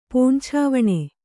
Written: ಪಂಚವಣ್ಣ

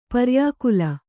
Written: ಪರ್ಯಾಕುಲ

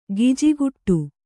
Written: ಗಿಜಿಗುಟ್ಟು

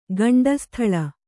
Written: ಗಂಡಸ್ಥಳ

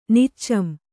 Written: ನಿಚ್ಚಂ

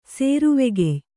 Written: ಸೇರುವೆಗೆಯ್

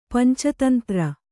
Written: ಪಂಚತಂತ್ರ

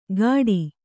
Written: ಗಾಡಿ

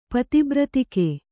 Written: ಪತಿಬ್ರತಿಕೆ

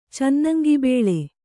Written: ಚನ್ನಂಗಿಬೇಳೆ

